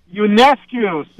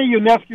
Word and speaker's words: Ionescu: Ionescu. – Sabrina Ionescu.